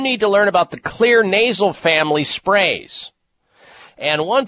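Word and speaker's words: Xlear: That's spelled with an X, X-L-E-A-R. The X is for xylitol.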